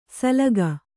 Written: ಸಲಗ

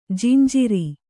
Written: ಜಂಝಾರ

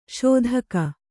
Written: ಸದೆಗ

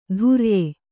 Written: ಧುರ್ಯೆ

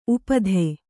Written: ಉಪಾಧಿ

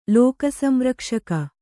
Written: ಲೋಕಸಂರಕ್ಷಕ

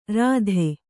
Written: ಋತಿ

